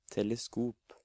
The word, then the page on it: teleskop: Norwegian